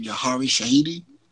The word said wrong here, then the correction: Yara Shahidi: Am I saying that correct? – You mean that, the person on Yara Shahidi.